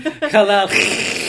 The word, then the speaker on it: Kalaallit Nunaat: who has taught us so much about Kala Glit Nudnat. Remember, it's blowing out, not in. Kala Nudnat.